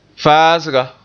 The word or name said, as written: faasga